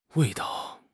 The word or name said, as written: wei4 dao5